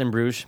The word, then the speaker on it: Bruges: It's pronounced In Bruges, but it's fine.